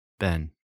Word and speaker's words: Ben: Ben, Ben Avery. My name even rhymes with friend.